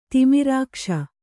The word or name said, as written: ತಿಮಿರಾಕ್ಷ